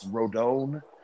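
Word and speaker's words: Rodón: Carlos Rodon. I don't think there's an N in there. I want there to be an N in there.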